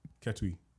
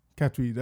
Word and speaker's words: Katwe: Katwe. – Katwe.